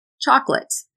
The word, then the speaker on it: chocolate: Chocolate. It actually sounds like two syllables, cha-clit.